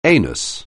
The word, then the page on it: anus: anglais